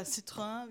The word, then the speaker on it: Citroën: Dann natürlich darf auch nicht fehlen, bei den Franzosen ein Citroën. Oder Citroën, wie es eigentlich richtig ausgesprochen ist.